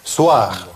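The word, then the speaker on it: soir: Сочетание ОИ читается как УА. Суар.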